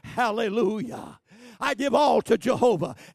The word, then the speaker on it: hallelujah: But the word itself, hallelujah, sometimes pronounced alleluia.